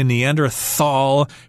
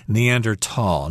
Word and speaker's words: Neanderthal: And I should mention that this is a German word, so some people say Neanderthal, pronouncing it the English way, but I think in German you're supposed to say Neanderthal, Neanderthals.